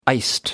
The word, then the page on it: iced: American English